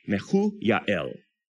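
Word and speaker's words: Mehujael: I know it's a J, but it's pronounced with a Y. Mehuyahel.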